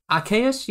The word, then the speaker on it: Arceus: How are we pronouncing this? Arceus? – Arceus, yeah.